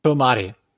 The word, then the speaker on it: Promare: But is it Promare or Promare? – Promare.